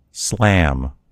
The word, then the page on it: slam: American English